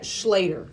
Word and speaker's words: Schlatter: I would pronounce that Schlatter. – Schlatter. – S-C-H-L-A-T-T-E-R.